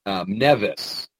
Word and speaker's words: Mnevis: In addition to the Apis bull, the Egyptians also worshipped Mnevis, spelled M-N-E-V-I-S, silent M, who was anthropomorphic, you know, with a human body and a bull's head.